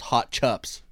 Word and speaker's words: hot chips: It says hot chips, but then it says in brackets, pronounced hot chups. – Yeah, C-U-P. Because we're lazy.